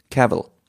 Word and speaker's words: Cavill: Äh, Henry Cavill oder Cavill? – Nein, es spricht sich aus. Er selber sagt, es gibt Videos, wo man sagt, wie spricht man deinen Namen Es wird ausgesprochen wie Travel. Cavill.